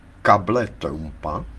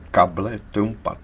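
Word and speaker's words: cable trampa: Stejně tuto situaci popsal přesně jako takzvaný plán kable Trumpa. – A to se vyslovuje možná kable Trump, ne? Ne, to se vyslovuje kable Trumpa, to je španělský termín značící nástražní drát k mině.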